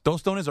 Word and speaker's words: tostones: Yeah, if I'm going to say it like a gringo, I'm going to say tostones. – Okay. – But it's tostones.